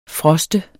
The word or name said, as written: froste